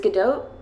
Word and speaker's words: Gadot: I go with Godot, but I'm also internally like, did I just say that wrong? – No, you said it absolutely right, because it's Godot, but it's like, you're kind of pronouncing the T silently, very quietly, but not totally ignoring it.